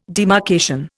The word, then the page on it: demarcation: Indian